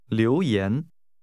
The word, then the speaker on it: 留言: 请跟着说, 留言, 言, 留, 留言, 留言. – This is pronounced with two rising tones.